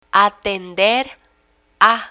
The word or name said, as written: atender a